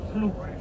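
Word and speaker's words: Flo: También, bueno, ribera del lago Stryn es el pueblecito de Flu, y la O en Noruega suena como una U. La O nuestra en Noruega ellos la pronuncian como una U, Flu.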